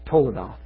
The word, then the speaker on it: toledoth: Yeah, toledah is T-O-L-E-D-O-T-H. Toledah. Maybe, is that Tom? I don't know you. Okay. Tom's better on the Greek. I think that's right. I think toledah is how it's said.